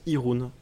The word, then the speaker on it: Jeroen: Et en fait, c'est un prénom néerlandais qui se, prononce Irun, mais on va, on va essayer d'alterner pendant le film et surtout l'appeler Koskov.